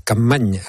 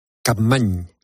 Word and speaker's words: Capmany: Eh, Capmany. – N y se pronuncia ñ en catalán. – O sea, Antoni. Bien. De Capmany.